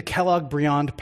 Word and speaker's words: Kellogg-Briand: you mean the Kellogg-Briand Pact. – Excuse me. – Which, thank you. – The Kellogg-Briand Pact.